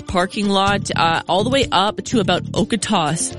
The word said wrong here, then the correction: Okotoks: Okotos, I always say it wrong. Okotoks. What is it, Josh? – I think it's Okotoks. – Okotoks. Thank you.